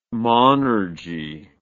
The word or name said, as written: monergy